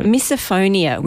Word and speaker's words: Misophonia: Misophonia, which translates as the hatred of sound.